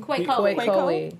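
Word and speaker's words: Kwekowe: It's K-W-E-K-O-W-E. A 23-year-old. – Quake Coley.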